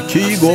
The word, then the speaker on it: Kygo: Det var Kygo, eller Kygo om man vill uttala det på norsk.